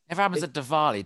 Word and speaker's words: Diwali: It's pronounced Diwali, bruv.